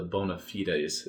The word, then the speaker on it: bona fides: So, real quick for the bona fides. Is that how you pronounce that? – Yes.